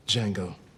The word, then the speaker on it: Django: Django. D J A N G O. The D is silent moving up to one of his newest ones, which is Once Upon a Time in Hollywood, you get to that mm-hmm.